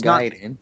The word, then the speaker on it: Gaiden: It's not Gaiden. Yeah. I want to pronounce it. It's Ninja Gaiden.